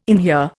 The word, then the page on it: inhere: Indian